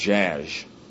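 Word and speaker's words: Szasz: His last name is spelled S-Z-A-S-Z. I believe S-Z in Polish makes a zh sound, so I guess it's pronounced Zsasz. Was scathingly summarized the price of hypocrisy in his book called The Manufacture of Madness.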